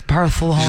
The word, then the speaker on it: Barcelona: Yes, Barcelona.